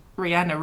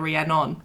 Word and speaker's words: Rihanna: Last week you called Rihanna Rhiannon.